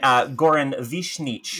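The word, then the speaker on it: Goran Višnjić: And also, let's not forget Goran Vishnich. – Yeah. – Which I Googled how to say it. – Oh, very good. Very good. It flowed out of your mouth naturally.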